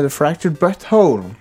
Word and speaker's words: The Fractured But Whole: Men når du uttaler det, så er det akkurat så det er The Fractured But Whole.